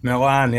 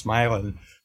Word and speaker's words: Milan: I grew up in Northern Ohio in a small town called Milan, like Milan, Italy, but pronounced Milan.